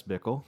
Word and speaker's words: Bickle: Travis Bickle. – Thank you. It rhymes with pickle.